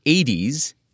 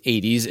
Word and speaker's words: Aedes: That's two Latin words, Aedes, A-E-D-E-S, and aegypti, spelled like A, Egypt, I.